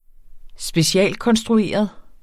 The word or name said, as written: specialkonstrueret